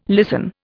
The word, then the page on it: listen: Indian